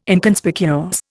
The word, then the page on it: Inconspicuous: Indian